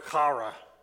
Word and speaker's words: chara: I listened to someone pronounce this, and it's very throaty. It's the word chara. Chara.